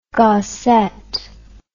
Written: Gasset

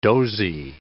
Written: dozy

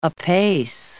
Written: apace

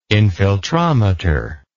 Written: infiltrometer

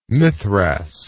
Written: mithras

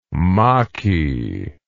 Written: maqui

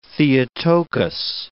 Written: Theotokos

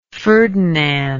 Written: ferdinand

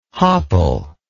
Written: hopple

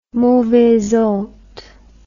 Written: mauvaise honte

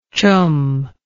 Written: choom

